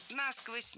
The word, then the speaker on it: насквозь: Если бы лед падал на дно, то у нас бы промерзали бы все водоемы, собственно, зимой насквозь, и весной бы размораживалось уже все стерильное. – Насквозь, неверно. Ударение в этом наречии должно падать на второй слог. Насквозь. Так зафиксировано в словарях, только насквозь.